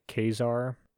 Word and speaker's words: Caesar: I know the correct way to pronounce it is actually like Kazar.